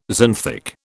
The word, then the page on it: xanthic: British English